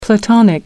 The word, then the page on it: platonic: American English